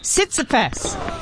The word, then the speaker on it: Tsitsipas: And while we're at it, talking about gentlemen tennis players, to Greece, happy 28th birthday to Stefanos Tsitsipas.